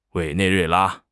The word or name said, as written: Wei3 nei4 rui4 la1